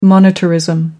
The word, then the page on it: monetarism: American English